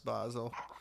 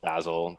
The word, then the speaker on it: Basel: They told me that it's pronounced Basel. One says Basel, the other says Basil.